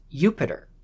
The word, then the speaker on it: Jupiter: But the thing is, a J in Latin actually has a Y sound, a Y. So Jupiter is more accurately pronounced as Jupiter.